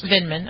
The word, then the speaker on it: Vindman: Vindman, Vindman.